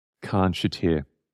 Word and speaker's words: Khanshatyr: So if you look up The Vampires, and it's featuring Chris Abrahams, and the track is called Khan Shater, which is spelt K-H-A-N-S-H-A-T-Y-R. Khan Shater.